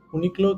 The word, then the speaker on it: Uniqlo: Uniqlo, que es como, se escribe como Uni, U-N-I-Q-L-O.